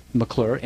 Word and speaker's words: McClure: McClure, M-C-C-L-U-R-E.